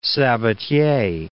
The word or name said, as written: Sabatier